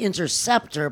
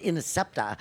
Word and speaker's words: Interceptor: It's actually Interceptor, but he always pronounced it Intercepta.